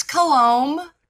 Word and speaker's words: Coulombe: It's Coulombe. – Coulombe. – My husband, okay. It's French Canadian, so the B is not pronounced.